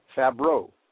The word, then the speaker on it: Favreau: Favreau.